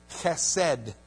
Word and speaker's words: chesed: It comes from the word chesed. You got to kind of make that sound when you say it. Otherwise, you're not saying it right.